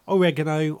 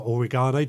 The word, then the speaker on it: oregano: Mint, various types, oregano or oregano, depends on how you want to pronounce it, sage, lots of rosemary, you name it, it's there.